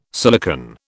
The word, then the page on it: silicon: British English